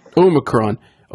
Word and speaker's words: Omicron: Omicron.